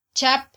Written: chap

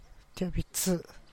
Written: tivaa bi tzi

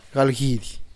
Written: galgidy

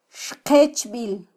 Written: xqechbil

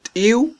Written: tiw

